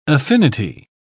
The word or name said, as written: affinity